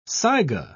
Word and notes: saiga: British English

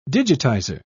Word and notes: digitizer: British English

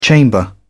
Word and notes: chamber: British English

